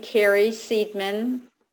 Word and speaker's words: Carrie Seidman: Um, so uh, the the address of the website is my name, Carrie Seidman, and you have to make sure it's spelled right.